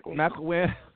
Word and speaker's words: McElwain: Harbaugh's 20-6 with a bowl win and a pair of third place finishes in the Big Ten East, while McWellen, is that how you say his name? Is 19-8 with a bowl McElwain. – Win. – McElwain.